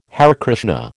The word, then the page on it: Hare Krishna: American English